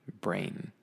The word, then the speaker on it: brain: So the thing in your head, your noodle, that is your brain. That's a long A sound, just like when you say the letter A, brain. But then the cereal, or the grain, the thing that's in cereal, is bran. Bran.